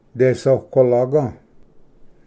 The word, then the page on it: ᏕᏐᏆᎳᎦ: desoqualaga